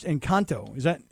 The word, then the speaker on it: Encanto: He goes, I hung around with my kids and I watched Encanto.